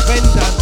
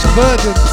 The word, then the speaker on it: Verdant: Or Vendant, as Mikey likes to call them. Verdant. You're mispronouncing the R as an N.